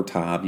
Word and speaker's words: Tov: Now, you have Mazel Tov, T-O-V, Tov or Tav, you can pronounce it either way.